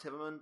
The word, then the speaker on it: Hive Mind: It's pronounced Hivemond, but it's spelled Hive Mind.